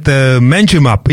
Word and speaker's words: Manjimup: All right, Manjimup.